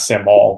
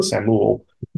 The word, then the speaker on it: Samuel: Not Samuel, but Samuel, like camel.